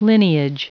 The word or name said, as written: lineage